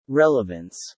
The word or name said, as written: relevance